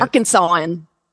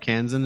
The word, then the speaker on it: Arkansan: Like you're a recognizable Arkansan. Is that the right way to say it? – Arkansan. It's an Arkansan. – Arkansan? – Yes, it. – Arkansan is the way to do it.